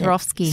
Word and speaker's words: Swarovski: Look, it's covered with, like, how do you pronounce Swarovski.